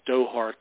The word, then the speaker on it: Doha: So the now is, you know, we have this administrative or diplomatic effort in Qatar, I think you correctly pronounced it, Doha, Qatar.